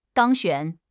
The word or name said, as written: 当选